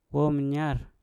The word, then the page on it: wom nya: mpur